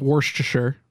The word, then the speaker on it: Worcestershire: First of all, it's pronounced Worcestershire.